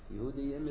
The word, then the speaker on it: Yehudiyim: Yehudiyim is the Kriya Ksiv, but even though it's spelled with two yuds in the Tanakh, but you pronounce it with one yud.